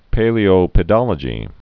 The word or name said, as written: paleopedology